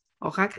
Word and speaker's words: Oaxaca: Oaxaca.